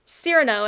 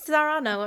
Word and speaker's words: Cyrano: That's like when I was gonna go see Cyrano, and I kept pronouncing it Serrano, and I was like, Serrano de Burjack.